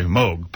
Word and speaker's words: Moog: Moog, pardon me, let's pronounce the name right.